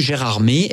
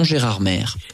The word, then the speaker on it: Gérardmer: D'où là une prononciation en fait qui s'est toujours maintenue, Gérardmer et non Gérardmer.